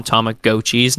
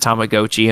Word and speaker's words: Tamagotchi: And then the commercials, they've brought them back, and like in the commercials, they call them Tamagotchis now. Like the lady pronounces it Tamagotchi, and I'm like, stop. – Oh, is that how it's supposed to be pronounced? – I know. No, it is definitely Tamagotchi. – It's Tamagotchi, yeah.